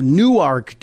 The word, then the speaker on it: Newark: In this post, Katherine Dettwyler of Newark, Delaware, I know to people in New Jersey and New York, it looks like Newark but down here in Delaware, it's pronounced New Ark.